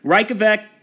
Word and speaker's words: Reykjavik: The capital and largest city, Reykjavik, note the J is silent in there, Reykjavik and the surrounding areas in the southwest of the country are home to over 2/3 of the population.